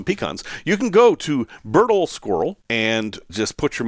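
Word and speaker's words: Berdoll: I hope I'm pronouncing that right. Bedroll. I actually thought it said bedroll, but it's B-E-R-D-O-L.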